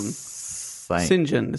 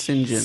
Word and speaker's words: St. John: St. John. It's pronounced St. John.